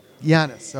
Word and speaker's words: Giannis: Giannis.